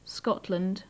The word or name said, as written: scotland